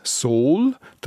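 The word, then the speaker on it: Sool: Normalerweise spricht man es mit langem O aus, Sol, darum schreibt man es an einem Ort auch mit zwei O, zum Beispiel eben beim Sol im Glarnerlang. Das Sol ist ein grosses Gebiet oberhalb von Schwanden,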